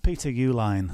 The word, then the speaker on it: Uihlein: Peter Uihlein.